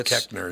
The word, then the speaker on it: Koechner: It's pronounced Koechner, yeah. – It's spelled Koechner. – Oh, Koechner, yeah.